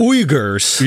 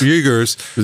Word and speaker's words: Uyghurs: Uyghurs? How do you pronounce it? – Uyghurs. – Uyghurs.